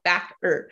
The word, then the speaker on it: Backer: Just like my maiden name was Backer, but everybody would be like, is it Baker? Is it Becker? I'm like, it's literally how it's spelled, Backer.